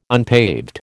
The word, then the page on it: unpaved: American English